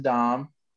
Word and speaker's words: Damm: I'm Jason Dahm. I play for Rugby Atlanta and the MLR. D-A-M-M, commonly mispronounced.